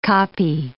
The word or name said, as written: koppie